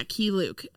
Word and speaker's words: Keye Luke: Let's talk about Charlie Chan's son, Lee Chan, played by the great Ki Luke. Is that how you pronounce that? – Yeah, Ki Luke, who is a Chinese-born American actor.